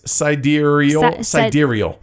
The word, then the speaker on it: Sidereal: Side Reel. – No, it's Sidereal. – Sidereal Confluence. – That's it. Sidereal Confluence.